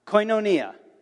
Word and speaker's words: koinonia: So I'm going to pronounce it for you syllable by syllable and let you learn it, okay? So I'll say it and then you repeat the syllable. Got it? Koi. No. Ni. Koinonia.